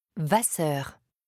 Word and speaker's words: Vasseur: G-R-E-T-A. – Et ton nom ? – Vasseur. – Avec deux S ? – Oui, c'est ça.